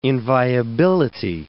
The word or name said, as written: inviability